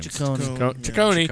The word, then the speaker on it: Ciccone: Ciccone.